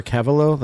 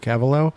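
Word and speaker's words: Keveloh: Trevor Kevalo. The last name is spelled K-E, V as in Victor, E-L-O-H, all together. So Trevor Kevalo.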